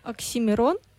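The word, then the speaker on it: Oxxxymiron: Okay, and how is it pronounced? – Oxymiron. – Oxymiron.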